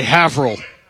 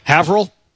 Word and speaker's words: Haverhill: H-A-V-E-R. – H-I-L-L. Just go ahead and say it. – Say Haverhill. – Try it again. – Haverhill. – You're close. – Okay. – Long A.